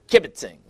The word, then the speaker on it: kibitzing: But they were kibitzing. Actually is the proper pronunciation of it.